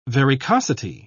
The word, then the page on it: varicosity: British English